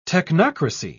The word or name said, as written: technocracy